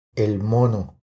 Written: El mono

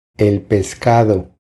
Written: El pescado

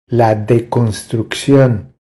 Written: La deconstruccion

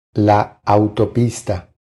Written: La autopista

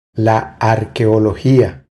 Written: La arqueologia

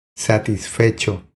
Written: Satisfecho

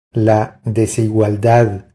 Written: La desigualdad

